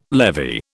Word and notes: levy: British English

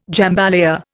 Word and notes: jambalaya: Indian

